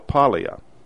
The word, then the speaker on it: Paglia: Her name is spelled P-A-G-L-I-A, so it would be Paglia, it would appear.